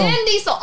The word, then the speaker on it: Vin Diesel: Why am I saying that wrong? – Are you sure he's your celebrity crush? – Vince Diesel. – Who's Vince Diesel? – Vin Diesel. Oh, Jesus Christ.